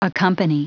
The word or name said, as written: accompany